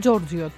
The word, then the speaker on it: Giorgio: Και φυσικά στα ιταλικά είναι Giorgio. Το προσπάθησα να το πω ιταλικά, ξέρω ότι δεν προφέρεται το γιώτα.